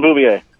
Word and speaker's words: Bouvier: Jacqueline Bouvier.